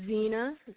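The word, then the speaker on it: Xena: My name is Zena.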